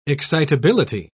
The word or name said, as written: excitability